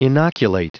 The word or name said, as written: inoculate